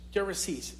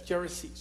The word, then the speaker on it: Gerasenes: From I think it's pronounced Gerasenes in English. Gerasenes.